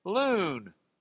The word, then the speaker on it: lun: Reason number two, the root for this Hebrew word for grumbling is loon. It's actually L-U-N, but it's a long U.